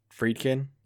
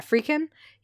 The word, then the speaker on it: Friedkin: Say his name. – Friedkin. – Yeah, Friedkin. I think I said Friedkin.